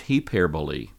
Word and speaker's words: hyperbole: Translated beyond all comparison, he's writing using an adjective that would be pronounced in English hyperbole. Hyperbole.